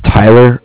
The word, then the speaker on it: Tyler: My name is Tyler. It's spelled T-Y-L-E-R. And I'm calling with Cardiff. It's spelled C-A-R-D-I-F-F, as in Frank Frank.